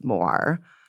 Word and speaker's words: Moir: I believe it's pronounced Moir, not like Moore.